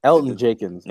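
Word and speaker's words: Elgton Jenkins: Elgin Jenkins. – Yeah, oh, okay. – Go. – The G is silent.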